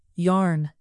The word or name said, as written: yarn